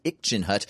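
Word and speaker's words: ircenrraat: And Ikchin Hut, spelled IrcenRaat, pronounced Ikchin Hut, are a part of Yupik folklore.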